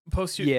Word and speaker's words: posthumous: Didn't Heath Ledger win a posthumous? – Yeah. – I don't know how to pronounce that. Posthumous. Yeah. Thank you.